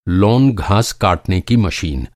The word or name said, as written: काटना